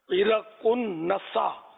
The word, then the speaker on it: عرقنسا: اس کا صحیح تلفظ ہے عرقنسا,